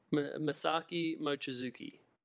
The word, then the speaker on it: Mochizuki: Masaki Mochizuki.